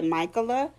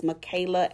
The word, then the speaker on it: Michaela: Oh, and speaking of that, in a previous episode, I mispronounced her. I'm sorry. Michaela Angela Davis. – Okay. – And, I think. I mispronounced her name. I said Michaela in a previous episode, so I want to make sure I change that.